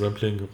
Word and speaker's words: Rampling: Bei der Rompling. wie spricht man es aus? Rampling, Rampling. Rampling, Charlotte Rampling.